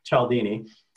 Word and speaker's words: Cialdini: And the author is Robert Cialdini. I actually had to look that up, how to pronounce his last name, but Cialdini.